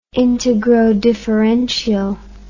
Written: integrodifferential

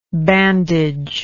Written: bandager

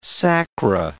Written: sacra

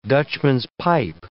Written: Dutchman's-pipe

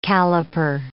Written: caliper

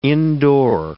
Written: indoor

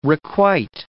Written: requite

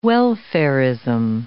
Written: welfarism

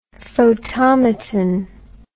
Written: photomaton

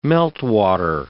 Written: meltwater

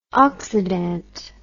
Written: oxydant

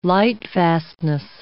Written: lightfastness

